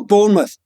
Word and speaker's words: Bournemouth: His name is Jordan Zemura he's at Bar- I'm going to mispronounce this. Burnmouth, Bournemouth? Oh, Bournemouth. Bournemouth.